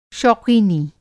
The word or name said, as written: शौक़ीनी